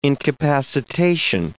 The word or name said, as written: incapacitation